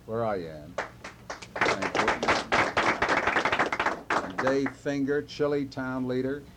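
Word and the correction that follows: Chili: We pronounce that Chilai.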